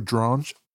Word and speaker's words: Dronge: 1952, you remember our man Alfred Dronge? – Rhymes with orange.